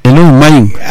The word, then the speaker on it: Enoumaïn: Tu prononces pas le U. – Enoumaïn.